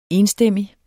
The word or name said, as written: ENSTEMMIGT